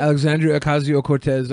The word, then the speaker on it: Alexandria Ocasio-Cortez: Here's, I hope I say her name right. Alexandria Ocasio-Cortez. Is that how you say it? – Alexandria Ocasio-Cortez. Yeah. I would say it like that, yeah.